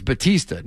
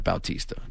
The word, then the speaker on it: Bautista: But, yeah, I would think that, by the way, I'm getting yelled at by wrestling fans by saying it's Batista, not Bautista. It's spelled Bautista.